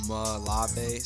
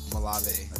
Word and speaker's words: Malave: It's pronounced Malave, so it's like Malave.